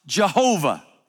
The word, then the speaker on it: Jehovah: and it's pronounced Jehovah.